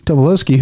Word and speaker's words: Tobolowsky: Like, but Stephen Tobolowsky, or however you pronounce his name. – Tobolowsky, who he's delivering the same kind of performance you see him deliver everywhere.